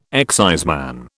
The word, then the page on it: exciseman: British English